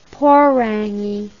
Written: porangi

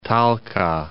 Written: Talca

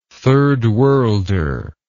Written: third worlder

